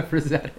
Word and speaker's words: Frazetta: Help me with that one. Frank Frazetta. Is that how you say it? – Yeah, Frazetta. Yeah.